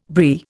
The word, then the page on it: Brie: Indian